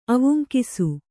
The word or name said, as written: ಅವುಂಕಿಸು